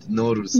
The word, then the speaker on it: Nowruz: How do you say New Year in Persian? – Yeah. – Nowruz? – Yeah, Persian New Year called Nowruz.